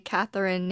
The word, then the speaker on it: Catharine: And I said this a few times, but my Catherine name is spelt differently.